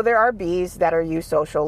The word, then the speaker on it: eusocial: Eusocial is spelled E-U-S-O-C-I-A-L. But I like to think of it as one bee asking another, eusocial, and the eusocial bee being like, yeah.